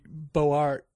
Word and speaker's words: Beaux-Arts: My grandparents grew up in that neighborhood, Beaux-Arts, and they pronounce it wrong because it should be Beaux-Arts, but that's the way they pronounce it in Bellevue, and it's just unbelievably gorgeous.